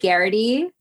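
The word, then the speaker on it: Geraghty: And then my Twitter is at CB Garrity, which is also my username for Clubhouse.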